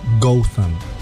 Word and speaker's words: Gotham: Sí, y que en inglés británico el pueblo se pronuncia algo así como Gotham.